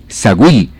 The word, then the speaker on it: Saguil: Saguil, no se les olvide ese nombre, Saguil, S-A-G-U-I-L, Saguil. Se pronuncia Saguil.